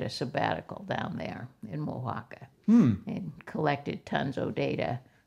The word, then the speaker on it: Oaxaca: I was going for Oaxaca. – Uh-huh. No, it's Oaxaca.